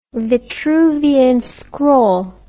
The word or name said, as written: vitruvian scroll